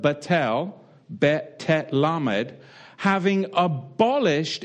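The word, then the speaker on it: batel (בטל): And it comes to us from the Hebrew word batel, batel. And that's spelt with a bet, tet, lamed, batel.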